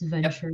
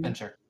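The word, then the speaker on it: Ventrue: That's how it's pronounced, right? It's Venture. – Yep, Venture.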